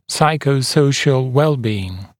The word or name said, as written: psychosocial well-being